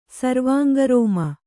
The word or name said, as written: ಸರ್ವಾಂಗರೋಮ